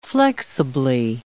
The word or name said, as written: flexibly